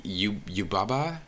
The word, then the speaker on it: Yubaba: He makes you think that, like, that Yubaba? – Yes. – Is that how you pronounce it? – Yeah.